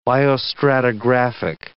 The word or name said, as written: biostratigraphic